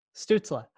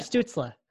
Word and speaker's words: Stützle: Stützle. – Tim, yeah. Stützle.